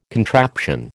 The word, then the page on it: contraption: American English